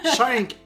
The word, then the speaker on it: Shink: Audrey Schenk, c'est comme ça qu'on prononce Non, mais je t'aime pareil, c'est Schenk. – Schenk, parfait, je vais essayer de m'en souvenir.